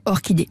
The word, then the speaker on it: orchidée: Bon, parfois le même CH se prononce que, comme dans orchidée.